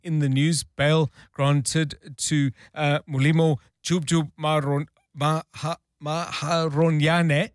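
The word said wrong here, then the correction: Maarohanye: Marohanya, there beg your pardon. Thank you, Nkosikona Malinga Mnisi, helping me out there with the pronunciation.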